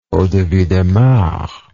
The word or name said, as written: eau de vie de marc